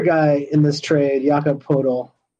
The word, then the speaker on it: Poeltl: No, there's no R in there. – Like a cat would do. – There's no R in there. – That's that. Hey, look at Basketball Reference's pronunciation. – Poeltl.